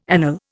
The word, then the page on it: anele: Indian